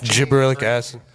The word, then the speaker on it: gibberellic acid: I believe it's pronounced gibberellic acid. It's called gibberellic acid.